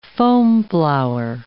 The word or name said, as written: foamflower